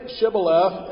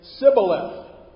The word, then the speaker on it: Shibboleth: And so so they would say to him, "Say Shibboleth," and he would say Sibboleth. He'd leave the H out. He couldn't say Shibboleth. It was Sibboleth.